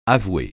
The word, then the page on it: avouer: francais